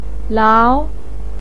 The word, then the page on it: lao2: pinyin